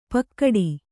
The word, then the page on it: ಪಕ್ಕಡಿ: Kannada